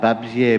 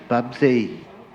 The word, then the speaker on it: Babsje: I was talking online to somebody many years ago whose name was spelled B-A-B-S-J-E. And I'd have pronounced that Babzie, but it was Babzie. It seemed like the sound was reversed for the last two letters. – Excuse me, Babshi?